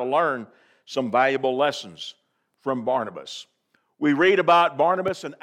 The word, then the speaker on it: Barnabas: So when you think Barnabas as a sort of an English name, that's how we pronounce it, but think of it for just a moment, if you would, as Barnabas, with an emphasis on that first syllable, bar.